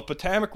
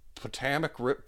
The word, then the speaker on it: Potomac: On the Potomac River. I've been saying that wrong the whole time. I read it in my head as like Potomac.